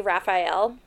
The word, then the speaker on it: Raphael: I also, another headworm that just got in my head, when we're watching this, they say Raphael. Which is how you say it.